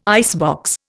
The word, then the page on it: icebox: Indian